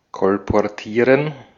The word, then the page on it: kolportieren: /kɔlpɔʁˈtiːʁən/